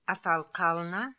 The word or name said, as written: Afalkalna